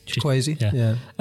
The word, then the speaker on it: Chukwueze: I'm going Samuel Chukwueze. – Chukwueze, yeah.